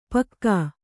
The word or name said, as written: ಪಕ್ಕಾ